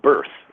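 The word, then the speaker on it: Bearth: It's called Birth, you know, just like... – Birth, but you spell it. – Yeah, it's B-E-A-R-T-H.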